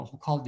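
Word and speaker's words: Hochul: Kathy Hokal galiba, Hokal diye okunuyor soyadı.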